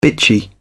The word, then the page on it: bitchy: British English